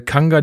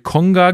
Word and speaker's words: Kanga: Dafür hat er äh, Kanga die ganze Zeit Konga genannt.